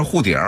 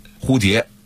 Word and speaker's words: 蝴蝶: 人就让你说啊二胡怎么说啊，可能有的人说二胡就是二胡啊，错了，北京话里的二胡一定是二呼，二呼，北京话里的蝴蝶一定是护蝶，绝对不是说北京话是护蝶啊，蝴蝶一定说护蝶，实际上这个字的这个普通话的正确的标准发音蝴蝶，但是北京土话里变了，变成什么了，蝴蝶，所以他说呢，就是这都是属于俄化的，这个俄化的发音，